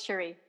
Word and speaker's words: Cherie: Yes, Cherie.